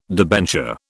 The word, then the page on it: debenture: British English